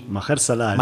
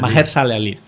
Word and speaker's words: Mahershala Ali: Y el que hace del pianista negro, si no me equivoco, es el Mahershala Ali. – Mahershala Ali. – Mahershala Ali. Sí, lo pronuncias tú mejor que yo.